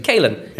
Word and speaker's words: Caolan: How do you say Lavery's first name? It's spelled Caolan. Caolan. Caolan, yeah. Caolan Lavery on loan from Sheffield United.